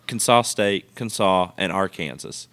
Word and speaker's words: Arkansas: It starts with Arkansas.